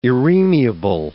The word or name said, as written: irremeable